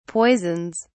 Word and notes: poisons: British English

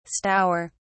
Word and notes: stour: British English

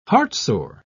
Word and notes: heartsore: British English

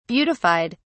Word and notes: beautified: British English

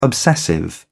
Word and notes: obsessive: British English

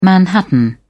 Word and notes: manhattan: British English

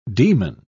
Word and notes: daemon: British English

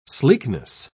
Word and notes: sleekness: British English

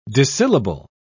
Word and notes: dissyllable: British English

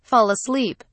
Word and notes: fall asleep: British English

